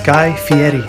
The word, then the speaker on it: Guy Fieri: Editor's note, it's pronounced Guy Fieri.